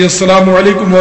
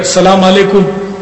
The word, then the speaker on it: السلام علیکم: اس میں بھی لوگ تلفظ کی بڑی غلطیاں کرتے ہیں، اب جیسے کہ سلام علیکم، سلام علیکم، مالیکم سلام، جواب والا بھی پیچھے نہیں ہے، وہ مالیکم سلام کہتا